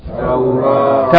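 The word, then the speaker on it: Taurah (توراة): If we stop on this word, we pronounce it? – Taurah.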